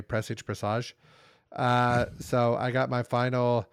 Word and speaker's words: Presage: Also got my final pattern of Beloved on the is it Prestige or Presage? How do you say that, Chad? – I would say Presage. – Okay, that's how I would say it too. – No. Osiris says it in game. He says Presage.